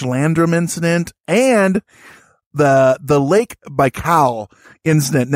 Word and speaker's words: Baikal: But I think it's Baikal, like Bai as in bicycle and Kal as in like Kal-El. Baikal.